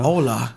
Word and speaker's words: Hola: Hola. – Hola. ¿Cómo estás? That's how white people say that shit. – Hola. – Hola. They pronounce the H. – Got the H is not silent.